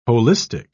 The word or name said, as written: holistic